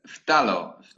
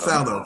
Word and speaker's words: phthalo: I never know how to pronounce because it's phthalo. – Oh, phthalo.